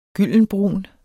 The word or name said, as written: gyldenbrun